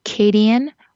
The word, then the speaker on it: Kadian: Or shoot me an email at Kadian, R as in red, G as in go.